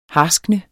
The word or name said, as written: harskne